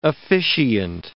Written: officiant

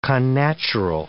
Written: connatural

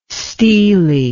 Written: steelie

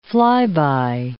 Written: flyby

